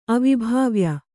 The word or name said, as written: ಅವಿಭಾವ್ಯ